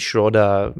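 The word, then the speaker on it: Schröder: They stayed in the game the whole time, even though Germans were making some crazy runs with their transition freeze and Dennis Schroder driving to the basket.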